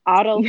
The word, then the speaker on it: AutoCAD: I know some people refer to it as a AutoCAD. Yeah. I hope I'm pronouncing this correctly. – No, you're correct.